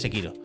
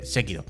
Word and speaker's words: Sekiro: Igual que, ah, igual que un día dijimos, no se dice Sekiro se dice Sekiro.